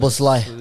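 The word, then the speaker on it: Szoboszlai: Soboslai. – Yeah, Soboslai, whatever.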